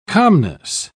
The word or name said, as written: calmness